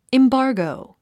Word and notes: embargo: imbάːrɡou; インバーゴウ

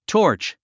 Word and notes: torch: tɔ́ː r tʃ; トーチ

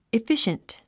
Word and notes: efficient: ifíʃənt; イフィシェント

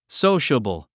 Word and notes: sociable: sóuʃəbl; ソウシャボォ

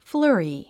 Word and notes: flurry: flə’ːri; フラァリィ

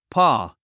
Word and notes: paw: pɔ’ː; ポー

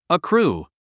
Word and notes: accrue: əkrúː; アクルゥ

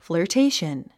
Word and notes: flirtation: fləː r téiʃən; フラァテイション

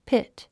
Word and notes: pit: pít; ピット

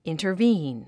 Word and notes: intervene: ìntərvíːn; インタァビーン

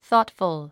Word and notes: thoughtful: θɔ́ːtfəl; ソートフゥ

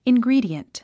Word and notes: ingredient: inɡríːdiənt; イングリィディエント

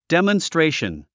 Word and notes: demonstration: dèmənstréiʃən; デモンストレーション

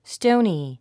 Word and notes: stony: stóuni; ストォニィ

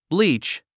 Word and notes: bleach: blíːtʃ; ブリーチ

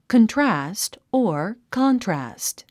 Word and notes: contrast: kəntrǽst; コントラスト

